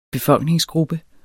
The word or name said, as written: BEFOLKNINGEN